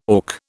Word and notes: auk: British English

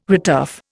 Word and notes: returf: Indian

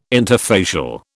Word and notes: interfacial: British English

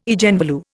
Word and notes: Eigenvalue: Indian

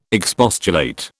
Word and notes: expostulate: British English

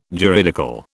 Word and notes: juridical: British English

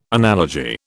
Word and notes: analogy: British English

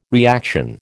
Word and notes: reaction: American English

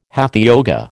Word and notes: hatha yoga: American English

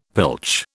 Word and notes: pilch: British English

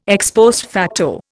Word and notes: ex post facto: Indian

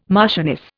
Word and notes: marchioness: Indian

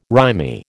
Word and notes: rimy: American English

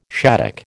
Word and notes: shaddock: American English

